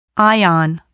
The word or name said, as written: ion